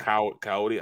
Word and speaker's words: Kayode: Oh, his name is Coyote Awosika. – Oh, your boy on the practice squad. Yeah. – Coyote Awosika. Yep. – But it's spelled like the K-A-Y-O-D-E or something. – Yep, K-A-Y-O-D-E. Yeah. And his last name, Awosika, A-W-O-S-I-K-A.